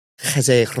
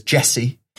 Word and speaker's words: Jesé: We do know that his name is pronounced Jesé Rodriguez, but it's quite hard to say repeatedly. And English fans referred to him as Jesse.